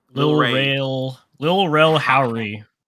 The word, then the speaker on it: Lil Rel Howery: But then Lil Ray how do you say his name? – Lil Rel Howery.